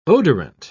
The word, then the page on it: odorant: British English